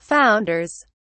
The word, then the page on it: founders: British English